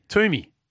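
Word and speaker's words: Twomey: Not Toomey, it's a silent W. – Toomey.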